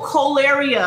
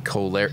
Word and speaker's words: cholera: I, I, I saw, I heard col- choleria. Uh, I think that's how you say it. – Cholera.